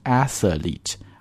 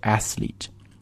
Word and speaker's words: athlete: 为什么呢？我们仔细来看一下这个单词的拼写，你会发现 th 这个 th 之后是没有元音出现的，所以你不要读成 athlete。而应该直接是 athlete 然后后面的这个 e 呢是一个长音的 e 啊